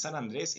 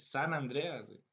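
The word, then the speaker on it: San Andreas: que no es San Andrés, es San Andrea.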